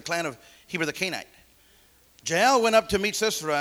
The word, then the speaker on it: Jael: Some might say Jael, but don't say it like that, guys. All right? Because it's Jah, Jehovah, El. Two words for God, banded together. What a beautiful name, Jael.